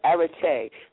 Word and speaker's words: Arete: So for all who are wondering, it sounds and it's spelled like Arete.